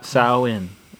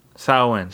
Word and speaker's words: Samhain: So it's like sow, like a pig, your favorite animal. – Right. – Samhain. I'll try to say it. Samhain. – Samhain.